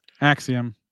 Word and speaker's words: Axiom: Featured Roman Reigns, Seth Rollins, Rhea Ripley, Grayson Waller, Axiom, and Naomi. Look, I got the name right. I could say it now. Axiom, right? That's Axiom.